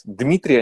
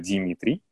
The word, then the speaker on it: Дмитрий: Дмитрий, они, например, не могут выговаривать, потому что у них дм, вот это им вообще произносить сложно. Поэтому они его называют Димитрий.